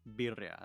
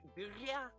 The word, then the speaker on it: birria: You said it's called birria? Is that how you say it? – Birria. You gotta roll your R's. It's kind of hard.